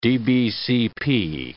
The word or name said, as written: DBCP